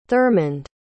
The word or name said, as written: thurmund